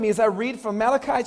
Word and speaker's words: Malachi: And it's pronounced Malachi, not Malachi. He was Hebrew and not Italian, all right?